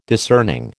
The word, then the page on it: discerning: American English